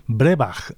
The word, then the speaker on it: Breabach: Continuamos con otra banda escocesa, en este caso Brebach. Se escribe Breabach.